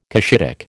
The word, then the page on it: Cushitic: American English